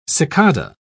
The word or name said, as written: cicada